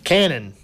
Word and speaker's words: Cannon: And Brandon, how do we say your last name? – Cannon, C-A-N-N-O-N.